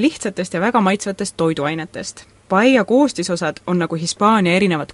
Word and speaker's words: Paella: mina õpetan Tartu Ülikooli tõlkekeskuses suulist tõlget ja mul on väga hea meel, et meie Paella Ekspressi hispaaniakeelsed intervjuud aitavad teieni tuua Tartu Ülikooli suulise tõlke magistrandid. – peame vist selgitama, miks saate nimi on Paella Ekspress. – Paella või nagu eestlased vahel hääldavad, paella on Hispaania toit, mida teatakse terves maailmas.